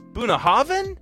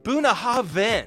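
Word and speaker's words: Bunnahabhain: Also, Bunahaven? The BH is a V? So I was way off.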